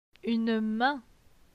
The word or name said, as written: Une main